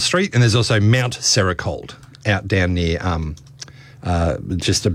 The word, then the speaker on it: Serocold: How do you spell that? – S-E-R-O-C-O-L-D. So I guess Sericold? – Sericold? I don't know. – Yeah. – Let's go with that.